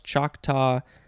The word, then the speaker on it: Choctaw: Um, this, well, so it says it's from their own language, their word, C A H T A. So I guess that would sort of be how it's spelled, but it's pronounced Choctaw.